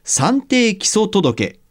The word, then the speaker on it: 算定基礎届: で、前回は労働保険の決算にあたる、まあ、年度更新についてのお話でしたが、今回は会社で働いている人のための社会保険と算定基礎届これ、計算の 3 に定めるに基礎の届けと書いて、算定基礎届と読むそうですけれども、えー、算定基礎届について伺います。